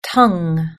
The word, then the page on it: tongue: British English